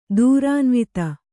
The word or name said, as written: ದೂರಾನ್ವಿತ